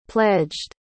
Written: pledged

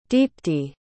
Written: deepti

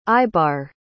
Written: eyebar

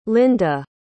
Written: lynda

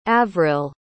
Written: avrill

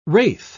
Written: wraith

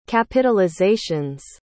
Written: capitalizations

